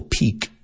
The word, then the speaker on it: pique: So do they sound the same? Okay. Pique, pique, pique. And peak, the mountain peak.